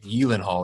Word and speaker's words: Gyllenhaal: You actually, the proper pronunciation of his name is like Gyllenhaal or something.